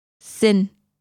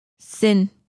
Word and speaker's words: xin: In Vietnamese, please is xin. Xin. Let's break it down by syllable. Xin. Now let's hear it again.